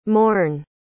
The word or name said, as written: mourne